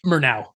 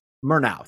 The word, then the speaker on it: Murnau: Murray. Is that right? Murnau. – Murnau.